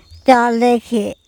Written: cOlekHe

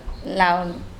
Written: lao

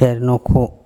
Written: ErnOkHo

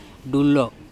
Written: DulO

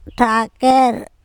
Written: THakEr

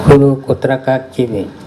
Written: kHuru kotrakak cibe

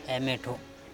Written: EmeTHo

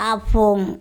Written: apHong

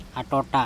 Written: aToTa

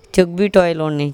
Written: cokbiToelone

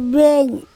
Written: beng

